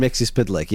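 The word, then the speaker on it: Mxyzptlk: Like, yeah, he's kind of like a button down Mixie Spidlick.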